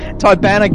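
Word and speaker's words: Tibanna: That's Tiberian. Tibanian. Tibana gas.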